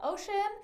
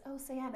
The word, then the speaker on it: Océane: And in the back of the book, I have, so Océane is spelled like O-C-E, with an accent, A-N-E. So it looks like ocean. Like when we go to the eye doctor here, they call ocean. And we're like, it's Océane.